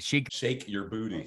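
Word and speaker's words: Sheik Yerbouti: I don't even know how you say it. – Shake Your Booty.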